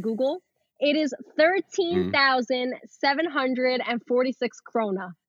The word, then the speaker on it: krona: Wait, so how do you say it? Um your currency is krona? – Uh yeah, krona or or crowns either.